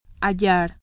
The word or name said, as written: hallar